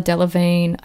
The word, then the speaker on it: Delevingne: It was people like Gigi Hadid, Carla Delevingne, I think that's how you pronounce her name. – Yeah, that's right.